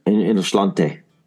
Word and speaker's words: Inno'sláinte: And my hourglass of, how do you say it again? Innoslint? – Innoslante. Yeah. Slante is the cheers.